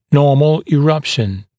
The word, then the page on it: normal eruption: ‘nɔːml ɪ’rʌpʃn; ‘но:мл и’рапшн